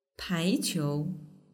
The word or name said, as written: paiqiu